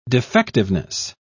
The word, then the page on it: defectiveness: British English